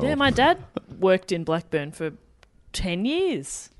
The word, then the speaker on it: Blackburn: Am I saying that right? – I think it's Blackburn.